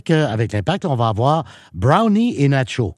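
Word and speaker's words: Browne: Oui, alors, il nous a expliqué ce matin que son nom, c'est Omar Brownie. – Brownie. – Exactement. Donc, Brown. – Le E, on le prononce, donc Brownie, et non Brown.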